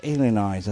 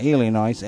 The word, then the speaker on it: Illinois: esto se dice Illinois, así hay que torcer la boca, Illinois,